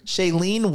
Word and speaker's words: Shailene: Is it Shailene? – Mm-hmm. – Shailene Woodley.